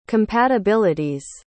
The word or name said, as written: compatibility's